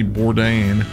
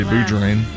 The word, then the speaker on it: Bourdain: And I never say his name right, but Anthony Bourdain, because I always say Boudrain.